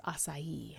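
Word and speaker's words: açaí: It's A-C-A-I. So I googled it, and sure enough, it's that everything that I could find was acai.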